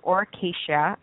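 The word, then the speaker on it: Aura Cacia: It's called Oracacia. That's the product name, actually, and it's lavender oil. It's spelled capital A-U-R-A, capital C-A-C-I-A.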